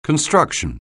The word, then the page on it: construction: anglais